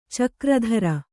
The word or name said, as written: ಚಕ್ರಧರ